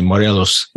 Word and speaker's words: Morelos: I was getting a certification in advanced Spanish and I was in Morelos, let me say it correctly, Morelos, Mexico.